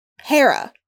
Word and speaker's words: Hera: But they the AI, and I didn't realize this at first, the AI is named H-E-R-A, which is Hera, like Greek mythology. But they pronounce it Hera, which I always thought was really odd.